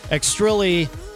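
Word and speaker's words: Xtrilli: Welcome Extrilli to the show. And again, it's X-T-R-I-L-L-I. I did have to check with them to make sure I was pronouncing it correctly.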